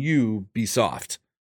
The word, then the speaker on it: Ubisoft: It's it's pronounced like you as in you be soft, but it's it's you're spelling it right. Yeah, it's Ubisoft.